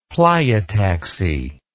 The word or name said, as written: pleiotaxy